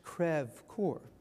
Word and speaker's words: Crèvecoeur: pronounced, not pronounced as we do, Crèvecoeur, but the French Crèvecoeur.